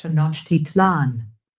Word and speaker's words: Tenochtitlan: Tenochtitlan.